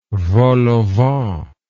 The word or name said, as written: vol au vent